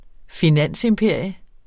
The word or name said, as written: finansimperium